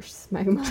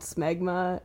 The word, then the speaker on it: smegma: Oh my god, there's a subreddit for shmegma. – Shmegma? How do you spell that? – S-M-E-G-M-A. – There's no S-C-H? Only two posts. – I've been saying shmeg. – No, smegma. Just smegma.